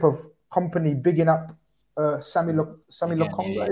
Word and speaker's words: Lokonga: Lokonga.